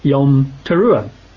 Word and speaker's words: Yom Teruah: Today, in Hebrew, they pronounce it as Yom Teruah.